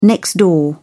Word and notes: next door: British English

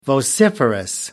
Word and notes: vociferous: American English

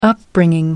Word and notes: upbringing: American English

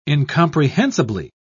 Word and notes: incomprehensibly: British English

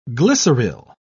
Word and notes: glyceryl: British English

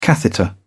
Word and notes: catheter: British English

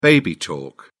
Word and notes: baby talk: British English

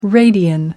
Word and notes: radian: American English

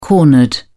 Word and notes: cornered: British English

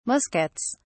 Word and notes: muskets: British English

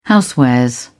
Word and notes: housewares: British English

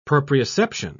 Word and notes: proprioception: British English

